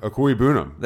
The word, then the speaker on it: Okwuegbunam: Actually, Travis Albert Okwuegbunam. – There we go. – It just rolls off the tongue, man. – Way better than Rich Eisen. Way better. – Yeah, Albert Okwuegbunam.